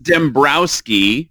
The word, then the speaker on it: Dembroski: We're super excited to bring on our pal Ryan, and I want to say Dembroski, but I every time I spell it, I'm like, it's Dembroski, like bro. – Well, it's pronounced Dembroski.